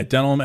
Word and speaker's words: Denholm: How do you say that? Denholm. – Yeah, Denholm Elliott. Yes.